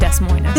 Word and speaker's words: Des Moines: It's pronounced Des Moines.